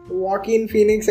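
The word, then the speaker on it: Joaquin Phoenix: क्या एक्टर का प्रोनाउंसिएशन है उसके नाम वॉक इन फीनिक्स है उसका नाम तेरे को हमेशा में करेक्ट करता है तो,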